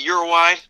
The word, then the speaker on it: Uruguay: I pronounce it Uruguay. Is it Uruguay? – It's funny. It's like if you speak to someone who's Hispanic and from the area, they'll say Uruguay. But I always said Uruguay, so I say both.